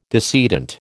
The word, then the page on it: decedent: American English